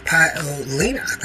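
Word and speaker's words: Paolini: Let's see, it's Jasmine Paolina. Paolina. That's how you pronounce her name.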